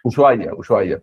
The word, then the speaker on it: Ushuaia: Se pronuncia Ushuaia, ¿no? – Ushuaia, perdón.